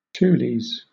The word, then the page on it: tules: British English